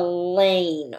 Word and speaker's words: lane: But we're going to call it a lane because when I sound out lane, I hear that long A sound. O-A-N. Lane. Lane has that long A sound just like r-ake and snake. Lane, just like snake and rake, even though you can only hear a few sounds, l, a, n, I hear three sounds. I want you to know it has four letters. It has that silent E at the end.